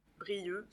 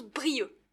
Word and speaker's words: Brieuc: Brieux, c'est-à-dire? – Avec un C à la fin. On écrit Brieuc, mais on prononce Brieux.